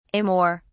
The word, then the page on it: amor: British English